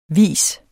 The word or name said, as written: vis